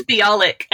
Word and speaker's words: Bialik: I think it's Bialik, I think is how you pronounce it.